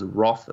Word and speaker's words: Wrath: I think the correct pronounce. You guys are saying Wrath, but I think the actual, when we said, hey, Google, you know, the stupid little Google speakers, she said it was Wrath of Man.